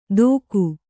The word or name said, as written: ತೋಕು